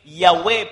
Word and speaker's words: Yahweh: Certains disent qu'il serait correct de prononcer Yahweh parce que dans les 22 lettres de l'alphabet hébreu, la lettre J n'existe pas.